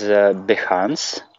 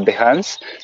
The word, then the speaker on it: Behance: Well, I think best is Behance, if I pronounce it correctly. – I think you have, yeah. – Yeah, Behance.